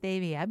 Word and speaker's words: stevia: So, anyway, stevia is this little plant, or it's also pronounced stevia because it originates in countries that's how they would pronounce it.